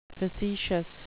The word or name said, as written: facetious